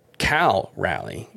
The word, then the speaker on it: Cal Raleigh: Kyle Raleigh, Cal Raleigh, excuse me, I don't know why I say it like Kyle, but Cal Raleigh, one for four with his ninth home run.